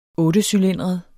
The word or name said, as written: ottecylindret